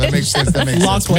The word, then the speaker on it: Loughlin: In 39 years of my life, I've thought that it was Lori Loughlin. Mm-hmm. And apparently you pronounce it Loughlin? – Yes. – L-A-U-G-H. – L-O-U-G-H-L-I-N.